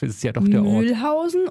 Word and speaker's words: Mühlhausen: Achso, die hintere, auf die hintere Betonung, ja, Mühlhausen.